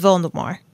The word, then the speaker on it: Voldemort: The author says it's Voldemort, like with no T, hard T sound at the end. More like how you'd say it in French.